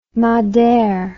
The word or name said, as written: madere